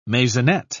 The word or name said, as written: maisonette